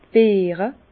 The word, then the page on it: d' Beere: pl.; Bas Rhin; Herrlisheim